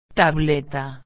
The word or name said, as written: tableta